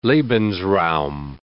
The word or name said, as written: lebensraum